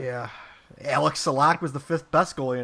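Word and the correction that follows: Salák: He wants me to correct you on the pronunciation. It's yeah. Alexander Sulak.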